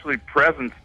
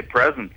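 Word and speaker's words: Prezens: Presens, P-R-E-S-E-N-Z. – Is that like the Pirates of Presens? – No, it's actually Presence, but I didn't want to spell it like that. So it's P-R-E-Z-E-N-S, right? And I just say Presence, and that's what I call it, and that's what the band is called.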